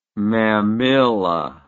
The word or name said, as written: mamilla